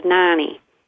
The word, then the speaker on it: Nani: But some people pronounce it Noni. But in the Midwest, you know, we have the short vowels, Nani.